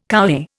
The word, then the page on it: kowhai: Indian